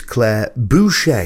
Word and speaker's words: Claire Boucher: is Claire Boucher, is how you pronounce it.